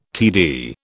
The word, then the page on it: TD: British English